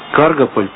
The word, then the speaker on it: Каргополь: Должно же быть какое-то куражное настроение авантюристическое, хотя бы потому, что вот Каргополь, правильное ударение делает? – Да.